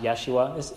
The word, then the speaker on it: Yeshua: Or perhaps you've heard it pronounced Yeshua.